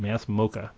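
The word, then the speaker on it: MASS MoCA: Please visit Megan's site, the Massachusetts Museum of Contemporary Art, MassMoCA.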